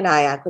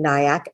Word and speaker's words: Nayak: So my Instagram is just my name at Tanya Nayak, which is spelled T-A-N-I-Y-A. And my last name, Nayak, N-A-Y-A-K.